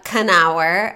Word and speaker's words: Knauer: I'm here with the stunning and gorgeous, fresh as a summer breeze, Leah Knauer, as I was told is pronounced, which yes. Is great to know because I would never know. I thought it was a silent K.